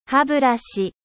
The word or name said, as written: brosse a dents